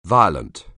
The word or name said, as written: violent